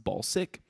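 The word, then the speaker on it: Balsac: I read somewhere it's pronounced Balsick, but it's spelled Balsack the Jaws of Death, which is Mike Dirks on guitar.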